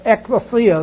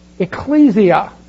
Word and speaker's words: ekklesia: Some people say ecclesia. They put their stress mark in another place. Ecclesia. E-K-K-L-E-S-I-A.